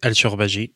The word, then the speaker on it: Alshorbajy: Comment est-ce qu'on prononce votre prénom et votre nom Raïs al-Shorbaji, en arabe Raïf, donc euh, signifie la première pluie après les sécheresses.